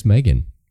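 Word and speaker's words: M3GAN: The next one is Megan.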